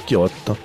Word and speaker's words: Kixot: Ça s'écrit Kixot, mais ça se prononce Kirotte.